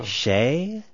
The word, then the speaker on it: Skeie: Shea? S-K-E-I-E.